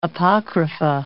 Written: apocrypha

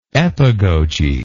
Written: epagogic

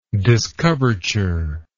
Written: discoverture

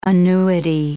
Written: annuity

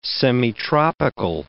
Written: semitropical

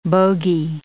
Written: bogie

